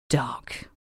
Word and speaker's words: d'Arc: In reality, her surname was just pronounced Dark.